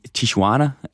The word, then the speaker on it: Tijuana: Og derfor så skal de bo i den mexicanske grænseby Tijuana, hvis udtalen er simpelthen korrekt. – Det er den.